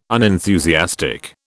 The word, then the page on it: unenthusiastic: British English